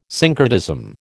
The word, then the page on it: syncretism: American English